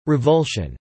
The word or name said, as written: revulsion